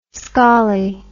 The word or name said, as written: skolly